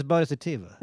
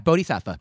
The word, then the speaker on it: bodhisattva: Oh, that's how you pronounce it? I thought it was bodhisattva. – Yeah, bodhisattva. Oh.